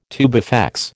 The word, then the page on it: tubifex: American English